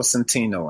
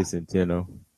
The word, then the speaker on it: Centeno: I mean, to be honest, looking at Centeno, how you say it, Centeno or Centeno, right? – I say Centeno. – Centeno.